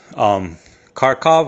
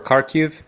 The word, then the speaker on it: Kharkiv: Now in Lugansk, the big city is Kharkov or Kharkiv. There's different pronunciations, so you're going to see them both because one is Ukrainian, the other is Russian.